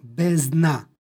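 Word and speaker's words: bezdna: Ona se uprošćava i ispada u izgovoru D, tako da izgovaramo bezna. Odnosno, D je gotovo nečujno. Bezdan, bezna.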